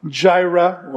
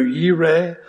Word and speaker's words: Jireh: Yahweh Jireh, or it's pronounced in Hebrew, Yireh, the Lord who provides.